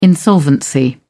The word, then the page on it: insolvency: British English